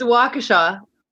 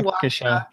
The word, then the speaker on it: Waukesha: It's, it's Waukesha. Wa- yeah, Waukesha. Waukesha.